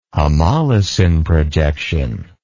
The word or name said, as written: homolosine projection